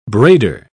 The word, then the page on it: braider: British English